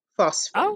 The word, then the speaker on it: phosphene: It's spelled P-H-O-S-P-H-E-N-E. Phosphine.